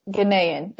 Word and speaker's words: Ghanaian: How did we decide you pronounce it? – I think Ghanaian. – Ghanaian. Yeah, okay.